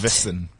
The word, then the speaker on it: Tufvesson: It all began when Sunday papers reported that the minister's romantic partner, Ingrid Tufvesson. – What? – I don't know if I'm saying it correctly or not. – Tufvesson. – T-U-F-V-E-S-S-O-N.